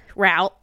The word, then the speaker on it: route: And so what they do is they like divvy up the route. What's the preferred route. Pronunciation, Cass? Root or route? Route, she says.